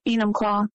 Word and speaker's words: Enumclaw: Enumclaw.